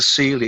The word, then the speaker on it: Silje: The girl singer is you pronounce it Celia, even though it's spelled S-I-L-J-E.